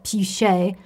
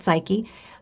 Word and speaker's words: psyche: In Greek, it's pronounced psyche, um, but we, we can call it psyche.